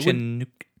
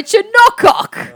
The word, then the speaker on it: Chinook: Chinook is spelt C-H-I-N-O-O-K. – I just said that. – Sorry. – All right. Can we get back on track? – Chinook. – Fine. – Chinook. – If it's Chinook, why isn't there a fucking U in it?